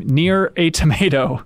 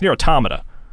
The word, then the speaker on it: Nier Automata: Nier a Tomato, as he called it. – And I believe it's... wait, how did you say it? It's Nier Automata.